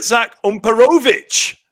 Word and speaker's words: Zach Umperovitch: I'll tell you, it's Mr. Zach Umperovitch, because I've been practicing.